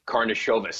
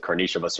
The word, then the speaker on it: Karnišovas: Arturas Karnišovas seems to be the way most people pronounce it. Karnišovas or Karnišovas from the Nuggets.